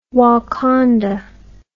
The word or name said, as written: Wakanda